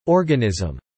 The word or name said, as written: organism